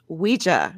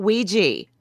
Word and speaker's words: Ouija: And yes, that is how it's pronounced. Ouija, not Ouija.